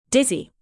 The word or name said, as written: dizzy